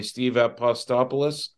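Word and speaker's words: Steve Apostolopoulos: Steve Apo, and I can never say his name correctly, so maybe one of you guys can for me. Steve Apostopoulos. – That's right.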